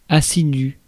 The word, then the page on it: diligent: /ˈdɪlɪdʒənt/